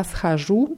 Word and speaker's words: схожу: Nur bei schadit ist es wichtig, die richtige Betonung da aufzupassen. Anna schodet immer auf O. Nur bei я схожу ist die Betonung auf U, sonst ist immer auf O.